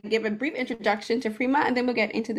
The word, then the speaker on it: frimas: Yeah, no, actually it's Frima. We don't pronounce the S in French, so it's Frima.